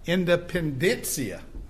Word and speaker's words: Independencia: Someone tells me how to pronounce that. Independencia. Okay.